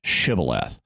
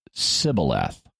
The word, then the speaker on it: Shibboleth: In Gilead, people would pronounce this word with a sh sound on the front of it, so it would be pronounced Shibboleth. But in Ephraim, they didn't say the word with the aspirated initial sound, and so they just used an S sound and said it Sibboleth.